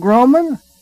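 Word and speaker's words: Groman: My name is Paul Grohman, that is spelled G-R-O-M-A-N, G-R-O-M-A-N.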